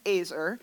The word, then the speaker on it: ezer: So the word that is used in Genesis for there was no helper suitable for him is the word Azer. It's spelled E-Z-E-R, pronounced Azer.